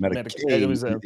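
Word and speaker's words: Matakevich: Matakovich, Matakovich, Matakovich. The accent is on the K.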